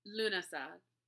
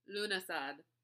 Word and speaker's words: Lughnasadh: According to me, it's Lunasad. Lunasad. According to me and me only. But it is spelled Lughnasadh.